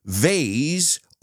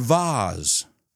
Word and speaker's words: vase: Ubiquitous or ubiquitous? I've heard it pronounced both ways by very intelligent people. So I guess that's up to you. Either, either. Neither, neither. Hey, these are words that have two pronunciations that are accepted everywhere. Another one the thing you put flowers in, vase or vase.